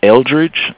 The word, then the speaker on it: Eldridge: His last name is spelled K-R-A-U-S-S. – And your first name? – It's Eldridge. That's spelled E-L-D-R-I-D-G-E.